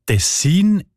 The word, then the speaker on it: Tessin: Ja, tatsächlich variiert die Betonung von dem Kantonsnamen, der sich übrigens vom gleichnamigen Fluss ableitet, von Region zu Region oder sogar von Person zu Person. Die Variante Tessin ist häufiger.